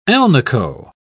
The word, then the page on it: alnico: British English